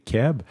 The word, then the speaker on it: Keb: Fait que là, les gens, pour parler de Québécois, vont dire Keb. Et l'autre jour, je l'ai vu écrit K-E-B. Là, j'ai fait, ben voyons donc, là, cibolac, là, lâchez-moi, là. Là, vous voulez vous enlever deux syllabes, puis là, vous enlevez trois lettres pour l'écrire.